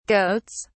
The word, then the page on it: goats: British English